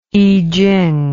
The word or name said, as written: i ching